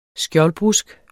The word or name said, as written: skjoldbrusk